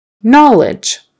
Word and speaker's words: knowledge: That K is silent. Knowledge, and that O is more like a short O. Na, ah, ah, knowledge. Don't really say the W in there. I mean, the word comes from the word know, right? Which has a W on the end, know, know. But when you turn it into knowledge, the W kind of disappears. Knowledge, and that G is pronounced like a J, and the E is silent.